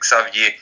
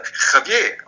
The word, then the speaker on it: Xavier: Das heißt, du kannst den ja auch zum Beispiel auf Französisch aussprechen mit Xavier. Zum Beispiel. – Der genauso. Also, genau. deswegen. Und, und in Mexiko sagen sie dann wieder Xavier.